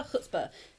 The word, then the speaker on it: Chutzpah: If you can look me up as Hannah, I am Hannah Chutzpah, C H U T Z P A H. Uh, silent C like Hanukkah.